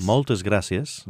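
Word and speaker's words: Moltes gràcies: Actually, how do you say gracias in Catalan? – Gracias. – Gracias. – Molt gracias. – Moltes gracias.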